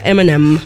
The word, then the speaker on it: Eminem: Nosotros en español creo que le decimos Eminem porque es, creo que así se escribe, ¿no? Pero en inglés se pronuncia Eminem. Porque la I, pues, I. Ya saben, Eminem.